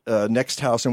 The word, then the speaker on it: NEXTHAUS: Tell us about Next House, and we'll get back to that award.